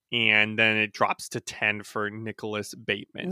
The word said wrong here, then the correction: Batum: It's Nicholas Batum, by the way, but I won't fault you for that because it's spelled like Bateman.